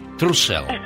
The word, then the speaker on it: Trussell: ¿Es Trussell o Trussell, Carol? – Ay, como lo quieras pronunciar. Creo que la segunda es mucho mejor. – Trussell.